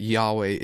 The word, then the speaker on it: Yahweh: I think most well most um, biblical scholars agree that Yahweh is is the more correct or the more accepted term um, of right. how to pronounce that.